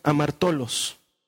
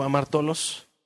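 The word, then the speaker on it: amartolos (ἁμαρτωλός): De hecho, cuando Pedro le dice, apártate de mí porque soy un hombre pecador, está utilizando una palabra griega que se pronuncia amartolos, amartolos. A ver, di conmigo, amartolos.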